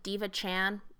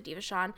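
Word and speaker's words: DevaChan: Yeah, it's D-E-V. Okay. And it's like there's like Diva Shawn, so it's like it's spelled like Diva Chan, but it's whatever. That's how I've heard people say it. It's like Diva Shawn.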